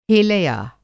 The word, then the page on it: हेलया: Hindi